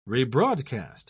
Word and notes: rebroadcast: British English

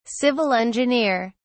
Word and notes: civil engineer: British English

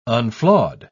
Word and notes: unflawed: British English

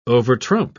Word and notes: overtrump: British English

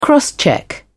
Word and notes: crosscheck: British English

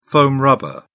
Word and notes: foam rubber: British English

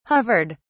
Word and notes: hovered: British English

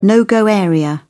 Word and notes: no go area: British English

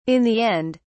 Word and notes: in the end: British English

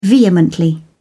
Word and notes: vehemently: British English

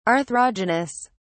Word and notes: arthrogenous: British English

